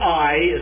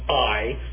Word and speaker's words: Ai: But in the next chapters, 13 through 25, Shechem is going to be mentioned, Bethel will be mentioned, Ai is how you pronounce that. We usually say Ai, and that's incorrect. It's the diphthong Ai, Ai.